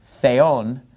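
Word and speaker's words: theon (θεόν): So notice, the word for God, theon, the accent is on the second syllable, that's where the mark is.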